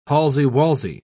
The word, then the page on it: palsy-walsy: British English